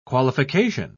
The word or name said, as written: qualification